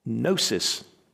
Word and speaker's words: gnosis: One is gnosis. It has a silent G. Gnosis is one way that you might pronounce it, but the word is gnosis, and it means knowledge.